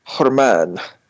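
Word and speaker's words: Germán: Well, it's spelled G-E-R-M-A accent N. – Oh, weird. – Herman.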